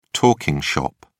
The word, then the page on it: talking shop: British English